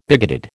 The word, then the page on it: bigoted: American English